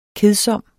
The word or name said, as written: kedsom